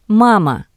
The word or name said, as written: Mama